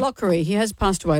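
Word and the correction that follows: Locorriere: Let's say his name correctly, she said, looking for the piece of paper. Lockerier.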